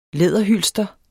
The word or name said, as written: læderhylster